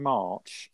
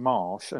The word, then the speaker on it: Marsch: I was unsure because you pronounced him March. I pronounced him Marsh, and I thought, well, look up how his name's spelled. Marsch. – Yeah. – It's open to interpretation, Jem.